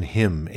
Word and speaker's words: hymn: Pronunciation hymn, H-Y-M-N, and that is a song for a nation.